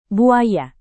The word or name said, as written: buaya